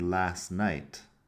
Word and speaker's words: last night: I went to bed early last night. Fíjate que no exactamente, no pronuncio exactamente la T en last. Ahí lo junto un poco con night y acaba siendo last night con la T no, suena.